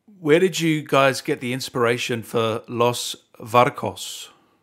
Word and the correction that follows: Los Vaqueros: I think you mean Los Vaqueros. – Vaqueros, sorry, yeah.